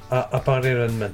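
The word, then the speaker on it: Appare-Ranman!: Appare Ranman.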